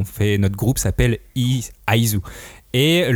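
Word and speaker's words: Is: Et l'auteur voulait pas ice parce que ça faisait trop penser à la glace, qui est le contraire de, son manga qui évoque la chaleur et l'amour. Ah. – Du coup, il faut vraiment prononcer Aizu. Et Ai d'ailleurs fait aussi penser à amour en japonais, etc.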